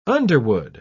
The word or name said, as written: underwood